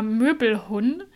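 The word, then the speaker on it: møbelhund: Und zwar Möbelhund. Also es schreibt man mit D am Ende, aber das D äh, spricht man nicht so richtig mit aus.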